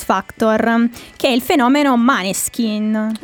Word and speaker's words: Måneskin: Cioè, quando è Maneskin, perché è facile per tutti, poi però se glielo chiedi dicono, ah no, insomma si legge Måneskin.